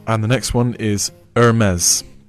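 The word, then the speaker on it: Hermès: 所以说啊，其实它正确的发音应该是 Hermes，不要把那个 H 发出来了，不是 Hermes，不是这样的哦。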